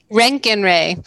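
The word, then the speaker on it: Roentgen Ray: Rotengen. Did I say that right? – Rankin-Ray. – Oh my God. Everyone's all embarrassed. I screwed that one up.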